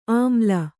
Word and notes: ಆಮ್ಲ: āmla

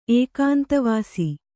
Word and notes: ಏಕಾಂತವಾಸಿ: kannada

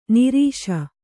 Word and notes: ನೀರಸ: Kannada

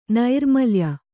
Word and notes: ನೈರ್ಮಲ್ಯ: nairmalya

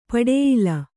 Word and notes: ಪಟ್ಟಾಯಿಲ: Kannada

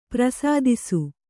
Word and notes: ಪ್ರಸಾದಿಸು: kannada

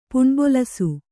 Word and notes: ಪುಣ್ಬೊಲಸು: Kannada

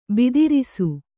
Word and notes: ಭದ್ರಿಸು: kannada

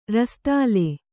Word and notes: ರಸದಾಲೆ: Kannada

